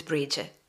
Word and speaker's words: Brigid: So, you know, if you're talking about more of like an Irish pronunciation, it's Brigid.